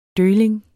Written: døgling

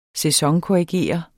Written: sæsonkorrigere

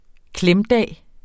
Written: klemdag

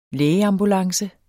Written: lægeambulance

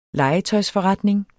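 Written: LEGETØJSFORRETNING

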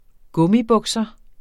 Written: gummibukser

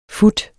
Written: fut

